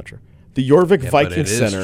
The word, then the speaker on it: Jorvik: Jorvik? – Yeah. Silent J.